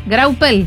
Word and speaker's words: Graupel: Graupel. – Sí. Se dice graupel. – Sí. – Una burra, yo lo dije toda la tarde mal. – No. – Yo lo leí. – Se sentó la primera sílaba.